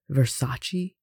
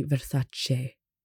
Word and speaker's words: Versace: It's the same with Versace, it's actually Versace.